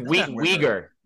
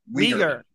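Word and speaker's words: Wiger: Next up, we have Carolyn Weiser. That's not how you say it. – But I think it's Weiger. – Weiger. Weiger. – Weiger. Per her dad's Wikipedia page.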